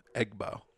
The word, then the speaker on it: Egbo: How do you say her last name? Egbo? – Egbo? – Egbo. – That's how I say it. – E-G-B-O.